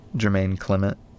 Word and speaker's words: Jermaine Clement: Jermaine Clement.